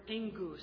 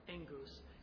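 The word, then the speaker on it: engus (ἐγγύς): And that's the third one is this Greek word engus, is how that's pronounced. There's no N in the word. It's just when you have two gammas together, it sounds like engus.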